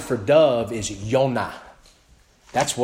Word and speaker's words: Yonah: Jonah, the Hebrew name Jonah means dove, Yonah. So it's easy for me to remember when I speak Hebrew because I know what Yonah means, dove. So, and that's how you pronounce Jonah in the Old Testament.